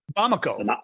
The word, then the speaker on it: Bamako: So you pronounced it by the name Bamako? – Bamako. Yes. Oh, right.